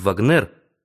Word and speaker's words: Вагнер: Интересно, в тот ли вечер этот Вагнер, ударение на последнем слоге, произвел над Бельбо свой сеанс психоанализа бесплатно, чего не осознали ни он, ни Бельбо?